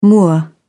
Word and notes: moor: British English

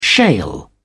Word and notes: shale: British English

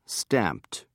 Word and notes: stamped: British English

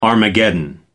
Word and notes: armageddon: American English

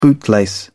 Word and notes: bootlace: British English

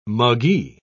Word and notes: muggee: British English

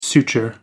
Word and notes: suture: American English

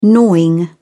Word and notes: gnawing: British English

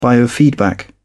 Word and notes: biofeedback: British English

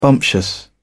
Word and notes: bumptious: British English

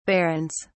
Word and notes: barrens: British English

